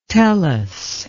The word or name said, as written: tellus